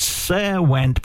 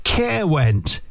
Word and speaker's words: Caerwent: It looks like it's Serwent, but it's probably Care as in Caerwent. It's spelled Caerwent. I'm going for Caerwent in South Wales.